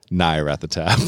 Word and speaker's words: Nyarlathotep: Now that is how I pronounce Nyarlathotep. – Nyarlathotep? Like with no L? – Nyarlathotep.